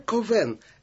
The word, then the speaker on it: Ковен: Отец Жерар Ковен, это первоначальное произношение имени Кальвин, Затем оно как-то... – Нет, это французское произношение.